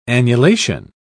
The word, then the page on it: annulation: British English